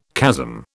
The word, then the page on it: chasm: British English